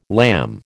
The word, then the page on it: lamb: American English